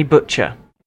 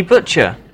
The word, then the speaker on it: Butcher: Ah, yes, Anthony Butcher. – I beg your pardon? – Anthony Butcher. – Could you spell that for me, please? – B-U-T-C-H-E-R, Butcher. – B-U-T-C-H-E-R.